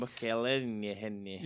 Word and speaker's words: McElhenney: Ryan Reynolds and Rob McElhenney, I've probably butchered that name completely. – McElhenney.